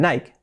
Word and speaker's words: Nike: Eso sería prácticamente correcto, pero ocurre que en Estados Unidos no dicen Nike, sino dicen Nike. No entiendo por qué, pero se pronuncia así, Nike. Le añaden una I al final, Nike.